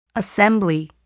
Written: assembly

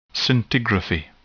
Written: scintigraphy

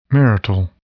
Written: marital

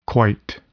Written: quite

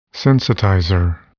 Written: sensitizer

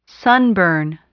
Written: sunburn

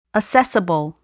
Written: assessable

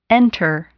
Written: enter

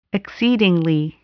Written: exceedingly

